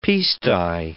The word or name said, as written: piece-dye